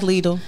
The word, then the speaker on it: Lidl: Lidl. Am I saying that right? – Lidl. – So I'm an Aldi fan. – I like Lidl.